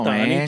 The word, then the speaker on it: Otani: Estoy hablando de Shoji Atani. Otani, chico. – Otani, chico dice O, no dice A, dice pero O.